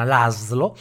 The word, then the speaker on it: Laszlo: quindi con SZ Laszlo che sarebbe l'Aslo, però eh, lo, lo, pronuncio proprio all'italiana, l'Aslo.